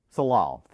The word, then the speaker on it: Tsalal: There is a research station nearby called Tesla's. I can't pronounce it. – How is it spelled? – T-S-A-L-A-L. – Salal. – Salal, thank you.